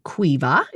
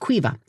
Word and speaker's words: Caoimhe: It says that the daughter, Cuiva, it's a Gaelic name, and I never would have guessed it from the way it's spelled, C-A-O-I-M-H-E, but Cuiva. So Cuiva seems to have some serious sensory issues.